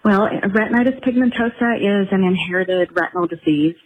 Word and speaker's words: retinitis pigmentosa: Is that retinitis pigmentosa? Did I pronounce that correctly? – You got it right. Yes, you did.